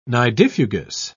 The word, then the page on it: nidifugous: British English